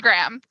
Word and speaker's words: Graeme: It's definitely Graham. – Perfect. So for anyone unique who doesn't spelling. Have the book in front of them, it's spelled G-R-A-E-M-E.